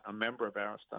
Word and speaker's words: Arista: Clive Davis takes over Bell, changes the name to Arista, and by the way, that's the correct pronunciation, right? – It is. – Okay, believe me, in these interviews I've heard it, Arista.